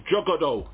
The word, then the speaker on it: Juggalo: I really like Juggalo. Have I pronounced that correctly? – Yeah.